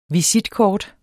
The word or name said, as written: visitkort